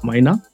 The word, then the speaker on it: Maina: Hi, my name is Wycliffe Maina.